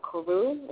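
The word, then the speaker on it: Koru: It's called Karu. It's spelled K-R-U, K-O-R-U.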